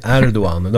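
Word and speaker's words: Erdoğan: Erdogan, det uttalas ju Erdogan. Det har man lärt sig. Med det här special G-et är det kanske. Och han har också Kılıçdaroğlu.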